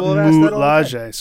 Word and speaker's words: Dora Milaje: Dora Milaje.